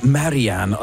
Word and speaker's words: Marianne: er på vej med et nyt album, og det her det er altså single-forløberen, der hedder Marianne. Eller det kunne også være, at man skulle udtale det på den engelske udgave, som vil være Marianne.